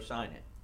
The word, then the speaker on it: signet: As you please, in the king's name, and seal it with the king's signet ring, for who whatever is written, is that pronounced signet or sign it? – Signet. – Signet? – Signet.